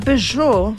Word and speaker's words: Peugeot: Y con la Peugeot, Landtrek.